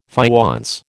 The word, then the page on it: faience: American English